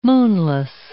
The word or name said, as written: moonless